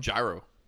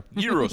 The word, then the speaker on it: gyro: Say it right. Go on. – Gyro. – Nope. – Gyro. – There you go. – Gyro sandwiches.